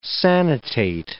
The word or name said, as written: sanitate